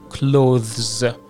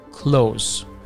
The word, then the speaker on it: clothes: دقت داشته باشید که ما معمولا اون th وسط رو تلفظ نمی کنیم و میگیم close.